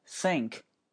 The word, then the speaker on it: think: 月份 month 只要有 th 都要咬舌，OK，思考 think。如果你不咬舌，会出现什么情况？我们就拿" 想" 这个单词来举例子。如果你不咬舌，发音就变成了 think，think。那这是什么？叫水池。你家洗脸的那个水池，洗脸池叫 sink，可以理解吗？